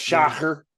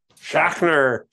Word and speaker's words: Schachner: It's like Schneider. – That's Schachner. – Schachner. Yeah. Schachner. Anyways, this is a cool cover, but for March 1936.